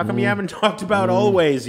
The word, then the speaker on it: Alvvays: Greg, I didn't get to mention always or always. Those two V's tend to confuse people. Always is how they pronounce it. A-L-V-V-A-Y-S is how they spell it.